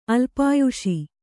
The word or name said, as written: ಅಲ್ಪಾಯುಷ